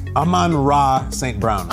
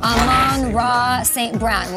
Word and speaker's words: Amon-Ra St. Brown: Amon-Ra St. Brown. – Amon-Ra St. Brown.